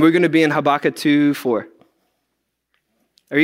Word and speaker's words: Habakkuk: So we're actually going to go into the book of Habakkuk. I think I said that right. Pastor Beth's not here, but she told me that it's Habakkuk.